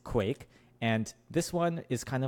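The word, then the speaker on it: Kwaiq: I called it the Kwai Q, which is just like not how it's pronounced. It's Quake. Because it's spelled K-W-A-I, capital Q. So it's stylized as if the Q is pronounced as its own letter, as its own syllable. That's not true.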